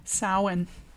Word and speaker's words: Samhain: until Samhain. Is that how you pronounce that? – Samhain.